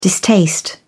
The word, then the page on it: distaste: British English